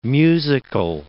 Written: musical

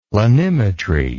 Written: planimetry

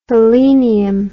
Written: helenium

